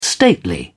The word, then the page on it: stately: British English